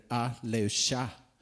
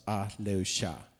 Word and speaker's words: Ahleucha: Den heter Aleusha. Det stavas A-H-L-E-U-C-H-A. Jag tror det uttalas Aleusha.